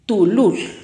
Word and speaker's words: tulul: Zapote en pocomá se pronuncia tulul. Tulul.